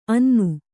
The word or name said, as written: ಅನ್ನ